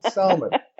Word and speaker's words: salmon: Of course, then everybody sends me words with silent L's in them. But I mean, that's, I usually tell people jokingly. It's like, why you got to disrespect the L? Salmon.